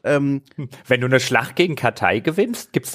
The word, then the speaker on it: Cathay: Und zwar dann gibt es noch die Kathai, ich spreche sie einfach mal so aus, man kann sie auch auf Englisch Cathay oder Cathay aussprechen.